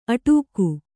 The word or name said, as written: ಅಟ್ಟಗೆ